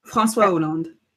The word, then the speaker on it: François Hollande: He was with a former French president, Francois Hollande. How do I pronounce that? – François Hollande.